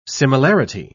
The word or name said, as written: similarity